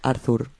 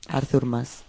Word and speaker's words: Artur Mas: Como le decían en la Audiencia Nacional, decían Arthur, Artur Mas. Como decían la pronunciación como si fuese inglés, ¿no?